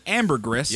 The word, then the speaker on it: ambergris: Better known as ambergris, yep. – is a valuable resource used by perfume makers to help a scent last longer. – I think it's just the S is silent.